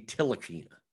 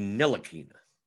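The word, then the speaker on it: Ntilikina: Okay, so Jonathan Laureano says it's a silent T. I always thought it was a silent N. I thought it was Frankie Tilakina. So it's Frankie Nilakina.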